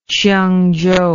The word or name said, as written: chiungchou